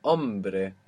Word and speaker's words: hombre: The word for man is hombre, hombre, HOMBRE, hombre.